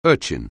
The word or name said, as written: urchin